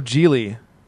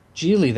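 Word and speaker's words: Gigli: Oh, Geely. – Geely, that's it? – Yeah. – Gosh, I didn't care enough to even learn Giggly.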